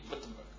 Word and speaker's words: Wittenberg: I think the W should be pronounced as V, Wittenberg.